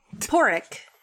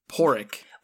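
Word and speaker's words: Pádraic: Oh, I don't know if they ever call him Patty, but it's Porrick. – Porrick. – Which is a way to pronounce, the Irish spelling of Patrick.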